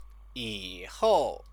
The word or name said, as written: yihou